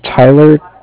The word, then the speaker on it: Tyler: My name is Tyler, T-Y-L-E-R, and I'm calling with Cardiff. It's spelled C-A-R. D-I-F-F, as in Frank Frank.